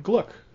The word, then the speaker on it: Glück: Yes, I looked up a video online of a bunch of people introducing her name, and they all said Glück. So I say Glück. Victoria tells me that is not how that name is pronounced in German, but I'll say what I always say, which is, I don't care what the Germans do.